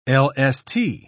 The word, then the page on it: lst: British English